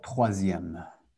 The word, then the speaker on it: troisième: Oh, troisième.